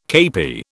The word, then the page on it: kepi: British English